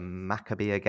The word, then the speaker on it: Maccabiah: Talking about something else, and I did read that you've recently participated in the Maccabiah Games. I hope I've pronounced that correctly. – Very good. Well done.